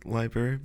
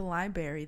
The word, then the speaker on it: library: I've never said library because there's an R after the B. – People just go straight into library, though.